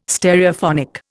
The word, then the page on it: Stereophonic: Indian